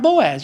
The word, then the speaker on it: Boaz: Boaz.